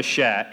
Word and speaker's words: sha: The next word that we have is sha, S-H-A, sha. The French word, which is the same word, is cher, C-H-E-R.